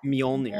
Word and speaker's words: Mjolnir: Mjolnir.